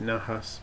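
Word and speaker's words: Najas: Kind of a lot for such a small tank, but I put just guppy grass, or it's called N-A-J-A-S. I pronounce it Nahas, but I think other people pronounce it other ways.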